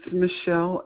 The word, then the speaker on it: Michelle: It's Michelle, M-I-C-H-E-L-L-E. The last name is spelled M-E-I-G-H-A-N, Meehan.